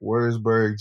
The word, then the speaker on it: Würzburg: Now I'm probably going to say this incorrectly, but is it Würzburg, Germany? Is that correct? – Yep, that's correct. Würzburg, Germany, home of Dirk Nowitzki and RJ Harris.